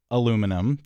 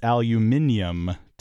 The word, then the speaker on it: aluminium: Yes, that is aluminum to us Americans. And for some reason that I still don't understand, it's aluminium to the Brits.